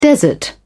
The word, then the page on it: desert: British English